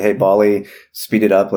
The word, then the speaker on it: Ballie: Yes, Bali. Not to be confused with Wally. – Or the actual Pacific Island Nation. – Yes, right. No, this is spelled B-A-L-L-I-E, Bali.